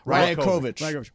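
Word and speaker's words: Rajaković: Rajakovic.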